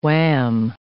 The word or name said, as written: wham